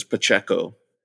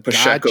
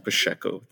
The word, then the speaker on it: Pacheco: No, it's a lot of people pronounce it in the Spanish version, which is Pacheco. But then Pacheco is Portuguese. Pacheco, the C, the S-H.